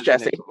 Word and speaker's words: Djesse: And those nominees are Jhené Aiko's Chilombo, Black Puma's Black Puma's Deluxe Edition, Coldplay's Everyday Life, Jacob Collier's Djesse Volume Three. Again, hoping I'm saying that right. I know that the D is silent, um, and it's supposed to. Yeah, it's Djesse.